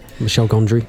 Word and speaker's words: Michel Gondry: Michel Gondry, that's how you say his name.